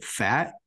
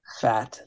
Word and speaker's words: Pfaadt: I don't know how to pronounce it. Fat. It's PFAADT. – Fat.